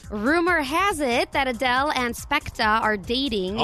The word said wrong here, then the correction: Skepta: No, wait, I said it wrong. Skepta.